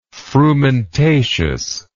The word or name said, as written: frumentaceous